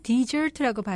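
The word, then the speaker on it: dessert: Desert는 사막이죠. 여기에 S가 하나 더 들어가면은 Desert라고 발음하는데요. Desert는 후식입니다.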